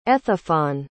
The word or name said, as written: ethephon